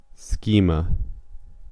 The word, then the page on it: schema: American English